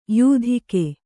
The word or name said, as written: ಯಾತಕ್ಕೆ